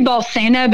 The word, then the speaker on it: Bolsena: What are some of the main things that you discovered in looking into your research for this book about the miracle of Bolenza? Am I saying that right? That led to the institution of the Feast of Corpus Christi. – Yes. Yeah. I say Bolsena, but I've learned in my Texas-ness that sometimes I pronounce things different in my head than they're supposed to be.